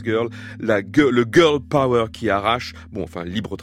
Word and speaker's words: P.O.W.A: Sur la vignette qui accompagne sa dernière chanson parue qu'on vient d'entendre, Power, P.O.W.A, comme on peut prononcer Power en anglais, il y a écrit Maya j'unis les gens depuis 2003.